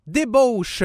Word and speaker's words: Débauche: Puis là, écoute, j'ai pas été correct, j'ai dit « débouche », mais je vais le prononcer comme il faut, là, je vais être correct, là. Débauche mobile, comme dans Vie de débauche.